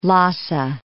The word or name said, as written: Lhasa